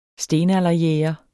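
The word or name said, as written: stenalderjæger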